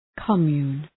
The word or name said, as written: commune